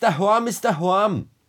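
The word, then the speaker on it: Dahoam is Dahoam: Ja, irgendwie da Horm ist da Horm, heißt es, glaube ich. Du kannst es besser aussprechen. Wie heißt es? Das heißt, da Horm ist da Horm. Ja, danke. Das läuft täglich, schon seit Jahren.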